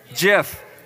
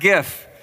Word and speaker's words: GIF: So it's technically not a word, but there is a correct pronunciation for it. Does anybody know what it is? GIF, not GIF. It's GIF, yes. It is GIF. I looked it up.